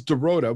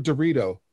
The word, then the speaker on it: Dorota: Yeah, and her name is Dorota, which is Polish Dorota. – It's like Dorothy.